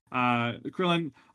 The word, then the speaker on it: Krillin: But I, Srillin? Did I get his name wrong? I did. – Srillin. – For some reason I want to add an S to the beginning of his name. Krillin.